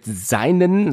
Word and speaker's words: Seinen: Soweit ich weiß, spricht man das Seinen aus. – Ich habe auch gesagt Seinen.